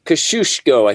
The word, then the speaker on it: Kosciuszko: Kosciuszko? – How do you say it? – Close. Yeah, Kosciuszko, I think is the preferred.